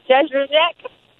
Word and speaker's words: Jędrzejczyk: I usually change it every time I say it, but it's like Joanna Jędrzejczyk.